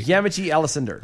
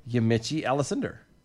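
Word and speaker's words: Yamiche Alcindor: Yamichi Alcindor. – Okay, once again, we always have to do phonetics. – Yes. – Right. We're going to do it phonetically. – Yamichi Alcindor.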